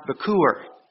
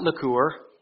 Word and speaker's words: bikkur: It's pronounced Bikur. Not Likur, but Bikur. B-I-K-K-O-O-R. In Hebrew, it's spelled B-I-K-K-U-R.